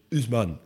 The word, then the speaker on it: Haussmann: Namelijk Georges Eugène Haussmann, of Haussmann, of je het in het Frans zou moeten uitspreken.